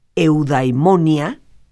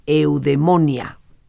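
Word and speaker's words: eudaimonia (εὐδαιμονία): Y en griego existe un término, eudaimonia, que en el griego moderno se dice eudemonia. Este concepto significa plenitud del ser. Esa es la traducción, y te lo vuelvo a repetir, en su original eudaimonia y en el actual eudemonia.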